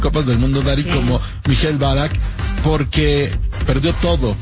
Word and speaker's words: Michael Ballack: ¿Cómo se dice bien? ¿Michael Ballack? – Michael Ballack, sí.